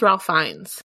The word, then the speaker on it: Fiennes: I think it's Ralph Fiennes. – Fiennes? – Like, you've got to find.